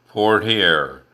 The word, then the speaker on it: Poitier: However, to begin with, I think it is important to consider how his last name is pronounced. I am speaking of Sidney Poitier, or, as it is more routinely pronounced, Poitier. It is a name descended from a French heritage.